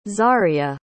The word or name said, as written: zarya